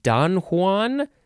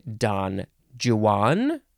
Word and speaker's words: Don Juan: You could say Don Juan or Don Juan. If you are chiefly British and in poetry, you might say it like that, Don Juan. You could say it lots of ways.